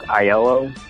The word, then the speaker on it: Aiello: Anyways, his last name is pronounced Aiello. I know because I have the same last name. – Aiello. Isn't that A-I-L-E-L-L-O?